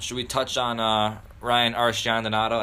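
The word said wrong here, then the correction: Arcidiacono: Archie Diacono out of Villanova.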